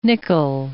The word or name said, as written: nickel